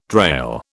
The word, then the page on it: drail: British English